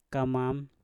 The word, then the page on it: kamam: Mpur